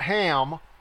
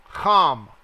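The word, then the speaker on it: Ham (חם): The sons of Ham, not Ham, Ham, That's how it's pronounced, like a ch. They just put a straight H there, and that is wrong.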